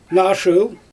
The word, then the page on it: naa shyl: Xyzyl